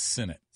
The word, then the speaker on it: Sinnott: By the way, we verified that the pronunciation is Sinnott. – Sinnott. – Yeah. – Oh, Sinnott. – Yes. – Sinnott. – It's okay. It's new to us. – What did I say? Sinnott? – Yeah. – I mean, it's spelled like Sinnott, but it's pronounced Sinnott.